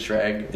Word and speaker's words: Schrag: And, what is it, Dean Schrag? That's how you pronounce it, Schrag.